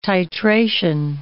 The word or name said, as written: titration